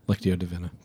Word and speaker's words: Lectio Divina: How do you say that? – Lectio Divina. – Divina. I did say it right.